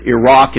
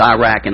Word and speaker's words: Iraq: To the east you had what we know is Iraq and, or I'll pronounce it correctly, Iraq and Iran, but I'm from the south. It's Iraq and Iran.